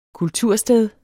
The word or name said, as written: kultursted